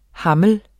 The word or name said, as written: hammel